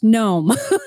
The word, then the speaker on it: Noem: I think they're saying that because you like to say her name with two syllables. – Noem. Noem. – Noem. – I know. – They're saying it's pronounced Noem.